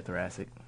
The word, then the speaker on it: thoracic: Markelle Fultz, you know, the Philly guard, he's out with, how do you say this, thoracic? – Yeah, thoracic.